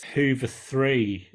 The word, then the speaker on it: Hooveriii: Hoover, is it, how would you say this? Is it Hoover-y? – I think it's Hoover 3 is what some people call them.